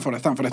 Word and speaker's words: Stanford: Stanford. Sí, lo pronuncio bien.